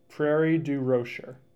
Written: Prairie du Rocher

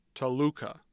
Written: Toluca

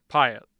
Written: Piatt

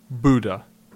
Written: Buda

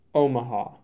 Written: Omaha

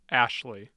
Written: ashley

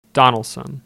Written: Donnellson

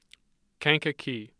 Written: Kankakee

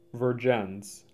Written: Vergennes

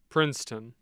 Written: Princeton

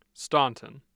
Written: Staunton

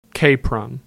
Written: Capron

